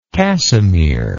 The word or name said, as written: casimere